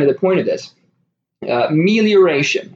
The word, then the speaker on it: melioration: The spelling is M-E-L-I-O-R-A-T-I-O-N. And as far as I can tell from what research online I've done, telling various different software pieces to pronounce it for me, I believe it is pronounced melioration.